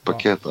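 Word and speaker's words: Paquetá: How do you pronounce his name? Is it Paqueta? – I would say Paqueta. – Paqueta, okay.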